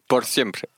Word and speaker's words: X100PRE: Por siempre.